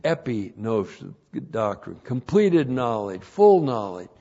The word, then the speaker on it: epignosis: Now, that word, knowledge of His will, is the word epignosis. E-P-I-G-N, long O-S-I-S.